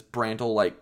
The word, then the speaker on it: Brandel: I can't remember what Beef said, but to correct me, I looked up Brandel's page on the OSU official roster, and it clearly says his pronunciation is Brandel, like spelling rhymed with candle. – Yep, so, Brandel, so like Beef, candle.